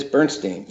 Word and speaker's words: Bernstein: It is Bernstein, yes.